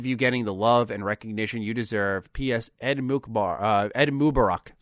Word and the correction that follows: Eid Mubarak: So I wanted to come back to this part here after a night's rest and make sure I fixed my pronunciation and say Eid Mubarak to anyone in our audience who is celebrating. Sorry again for completely butchering how I said it originally.